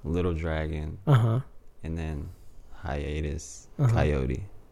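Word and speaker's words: Kaiyote: Hiatus Cantaloupe. Hiatus Coyote.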